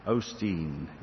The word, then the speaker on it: Osteen: Is it Osteen? Is that how you say his name? Osteen. Yeah. Your Best Life Now: 7 Steps to Living Your Full Potential.